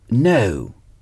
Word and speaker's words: know: C'est la même prononciation que pour le verbe know. I know. Je sais. Je sais. Je sais. I know. Know s'écrit K-N-O-W.